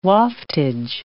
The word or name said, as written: waftage